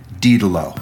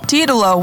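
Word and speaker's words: Didulo: In her very first video, she pronounces it herself, and she pronounces it Didelo. – Didelo, wow, I was way off.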